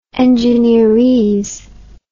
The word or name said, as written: engineerese